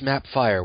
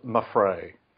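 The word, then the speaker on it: Mapfre: Is it Mapfrey? I thought it was Mapfire when I first saw it. – It's pronounced Mapfrey. – Oh, excuse me. – The P is silent, apparently.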